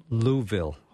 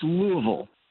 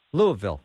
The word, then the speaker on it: Louisville: How do I close on it. It's Louisville. – Louisville.